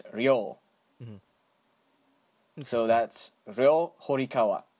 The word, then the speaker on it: Ryo Horikawa: I mean, if you're going for a Street Fighter type of pronunciation, you'd probably say Ryo, but no. The Japanese language, it has some weird pronunciations when there's a one, like, kind of letter or symbol, mm-hmm. and they combine other small symbols to make another sound. Mm. In this case, it's a ri, R-I, and right next to it would be a small yo, y-o, and this makes the sound ryo.